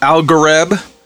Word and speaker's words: Algorab: And everybody pronounces it Algareb, but it's spelled A-L-G-O-R-A-B, right? Yeah. Algorab, but everybody says Algareb.